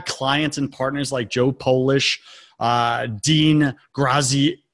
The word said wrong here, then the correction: Graziosi: Dean Graziosi, Genius Network, and more.